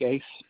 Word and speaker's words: Uys: My name is Craig Ace. Mm-hmm. My last name is spelled U-Y-S.